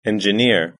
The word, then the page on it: engineer: British English